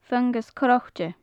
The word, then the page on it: fungas crochte: gaelic